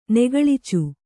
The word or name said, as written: ನೆಗಳಿಚು